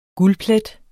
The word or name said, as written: guldplet